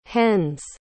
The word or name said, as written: hen's